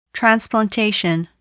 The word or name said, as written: transplantation